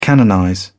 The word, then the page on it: canonize: British English